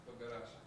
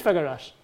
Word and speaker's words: Făgăraș: So Arad, how do you say this one? – Sibiu and? – Făgăraș. – It is Făgăraș.